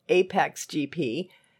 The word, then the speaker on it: APXGP: The brilliant no BS technical director for APXGP, pronounced Apex GP, the team he has joined at the behest of its desperate owner, his old racing buddy Ruben Cervantes, played by Javier Bardem.